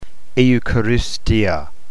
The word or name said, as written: eucharistia